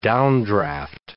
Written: downdraft

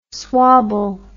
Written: swobble

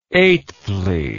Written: eighthly

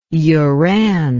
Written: uran

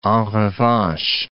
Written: en revanche

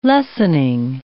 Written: lessoning

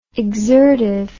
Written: exertive

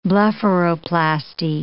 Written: blepharoplasty

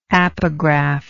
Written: apograph

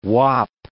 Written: Uap

